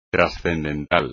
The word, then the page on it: transcendental: espagnol